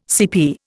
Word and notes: CP: Indian

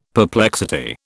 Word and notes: perplexity: British English

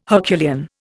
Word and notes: Herculean: Indian

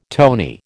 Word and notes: tony: American English

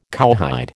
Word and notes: cowhide: American English

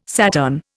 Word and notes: Saturn: Indian

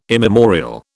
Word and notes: immemorial: British English

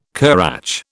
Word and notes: currach: British English

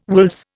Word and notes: wolves: Indian

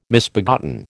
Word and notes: misbegotten: American English

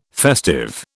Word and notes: festive: British English

